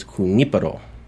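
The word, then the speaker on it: Junípero: The actual Spanish pronunciation is Junipero.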